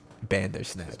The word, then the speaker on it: Bandersnatch: I said Vandersnatch that whole other episode, but people have like later on told me that it's Bandersnatch. – It's Bandersnatch, bro. – Yeah, I was just doing the Spanish pronunciation, dog, replacing the B with a V.